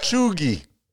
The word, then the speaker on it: cheugy: The actual pronunciation of that one is Cheugy.